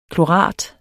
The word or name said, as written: klorat